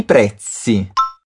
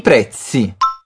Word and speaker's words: prezzi: I prezzi. Capricha bem no som do Z. É a mesma pronúncia do Z na palavra pizza. – Mais uma vez. I prezzi.